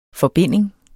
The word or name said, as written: forbinding